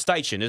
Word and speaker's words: Steichen: Is it Shane Steichen? Is that how you say it? Right, yeah. – It's Steichen, isn't it? – Mm-hmm.